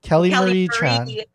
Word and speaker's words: Kelly Marie Tran: Kelly Marie Tran.